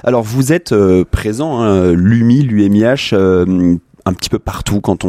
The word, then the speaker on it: UMIH: On peut dire les deux, j'avais entendu UMI, c'est pour ça que j'ai commencé comme ça. – Ça s'écrit UMIH.